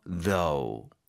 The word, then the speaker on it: though: 그러니까 하지만 그렇지만이라고 하면 but 이러고 시작하면 되지만 but 대신에 문장을 먼저 쓰고 나서 though 라고 써도 의미는 같죠. – 네. 그리고 TH 발음 신경 써주셔야 돼요. 음흠. 혀가 이 사이에 진동하는 느낌으로 though.